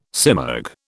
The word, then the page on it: simurg: British English